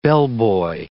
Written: bellboy

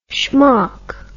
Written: schmock

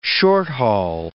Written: short-haul

